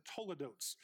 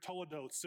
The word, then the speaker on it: toledots: there's a list of toledotes. Toledotes is spelled T-O-L-E-D-O-T-S.